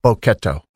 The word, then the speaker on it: Bochetto: Bochetto.